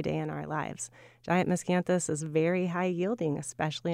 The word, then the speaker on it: Miscanthus: All right, for those of us who are not as familiar with Miscanthus, say more than I did in the introduction just then about what it is.